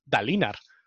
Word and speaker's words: Dalinar: como se tendría que pronunciar es Dalinar, ¿sabes? Porque eh, acabado en R y tal, o sea, en fin, por el tema de, la lengua española, eh, tendría que ser a, a, Dolin, creo, ¿no?